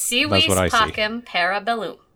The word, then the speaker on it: Si Vis Pacem, Para Bellum: Seaweed Pacem Parabellum. – Yep, Seaweed Pacem Parabellum.